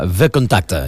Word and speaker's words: VKontakte: Molt bé, per tant, doncs, veurem què passa amb VKontakte, és tal com hauríem de pronunciar-ho, que és aquesta app molt coneguda a Rússia i que Apple ha retirat de les seves plataformes.